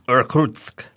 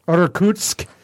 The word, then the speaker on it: Irkutsk: how would you pronounce that? Uh, uh Go to the teacher, always. – Irkutsk. Irkutsk, Russia.